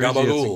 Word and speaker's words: gabagool: And, Capicola, in New Jersey, gabagool. It's gabagool.